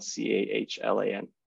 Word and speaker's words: Cahlan: My name is spelled kind of weird, but it's Cahlan, or you can find me on Twitter. Same thing, Twitter/Cahlan, Cahlan.